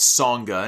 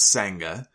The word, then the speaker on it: Sanga: Grayson Waller's bodyguard, I guess it's Songa and not Sanga, even though it's spelled that way.